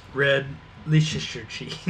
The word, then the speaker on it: Red Leicester: How to pronounce red Leicester cheese.